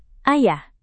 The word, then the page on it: ayah: indonesian